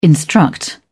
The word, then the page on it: instruct: British English